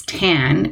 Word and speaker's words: Tan: And this patient had been nicknamed Tan, and it's actually spelled T-A-N. And so if you're Anglophone, you might look at it and say it's Tan. But remember, this person was French, so it's actually pronounced Tan, like time.